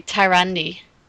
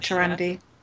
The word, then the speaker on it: Tyrande: The best boobs in-game are on Tyrande, or Tyrand, or however that's pronounced, in some of the artwork. Some of the artwork, how do you pronounce that? – I always say Tyrande. – Yeah, I've always said Tyrande.